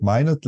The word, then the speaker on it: Minot: Minot Light Consulting? – It's actually Minot Light, and it is a lighthouse in the town that my business partner and I live in, Scituate, Mass.